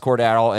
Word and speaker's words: Cordarrelle: Give me one more, and then I want to expand on Cordarrelle Patterson, who is pronounced Cordarrelle and having an excellent year so far.